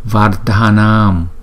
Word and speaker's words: Vardhanam: Chữ DH chúng ta đọc là Dh, và chữ A kéo dài chúng ta đọc là A Nam Nam Vardhanam Vard Var, có chữ R nữa nha.